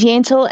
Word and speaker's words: Yentl: My name is Yentl and it rhymes with Gentle.